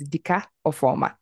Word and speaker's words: Dika Ofoma: His name is Dika Ofoma.